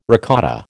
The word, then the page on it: ricotta: American English